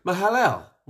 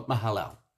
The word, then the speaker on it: Mahalalel: Mahala. I think I'm pronouncing that right. Mahalel. We'll call him Mahalel. It's spelled M-A-H-A-L-A-L-E-L. Yeah, we're going to go with Mahalel.